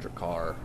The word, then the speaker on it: Drakkar: I pronounce it Drakkar. – Oh, like Drakkar Noir, the cologne.